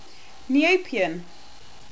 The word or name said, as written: Neopian